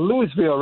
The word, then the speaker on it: Louisville: I thought it's spelled Louisville, right? – Yes. Mhm. – Yes, but that's how they pronounce it. Louisville. But that's colloquialism.